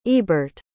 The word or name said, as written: ebert